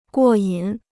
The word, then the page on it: 过瘾: guò yǐn